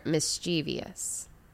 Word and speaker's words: mischievous: So they're mischievous. – Mischievous is the pronunciation.